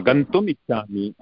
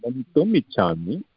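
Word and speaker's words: गन्तुम् इच्छामि: Aham Austin gachani gachanti how do I say that? – Gantum ichhami. You want to go? Gantum ichhami.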